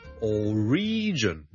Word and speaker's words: origin: number two 呢，如果读成 origin 呢就会怪怪地啦，因为个重音其实 origin O-R-I-G-I-N 个来源呢，我哋个重音系第一个音节嘅，所以 single law origin。